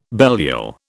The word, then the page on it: Belial: British English